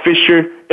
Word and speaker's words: Fisher: My handler name on Facebook, Twitter, Instagram is LeVar Fisher, LeVar Fisher, FISHER.